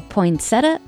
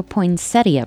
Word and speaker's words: poinsettia: Are you a poinsettia or poinsettia person? Both pronunciations are correct, and today we celebrate National Poinsettia Day.